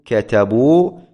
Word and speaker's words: كتبوا: فنقول كتبوا فعل ماض مبني على الضم لاتصاله بواو الجماعة، والواو ضمير متصل مبني على السكون في محل رفع فاعل. بعد هذه الواو تكتب ألف، تكتب ألف، تكتب ولا تلفظ.